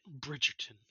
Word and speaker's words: Bridgerton: No, I feel like it's got kind of a ring to it, and you said it right, Bridgerton.